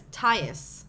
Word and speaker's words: Tyus: His name is Tyus.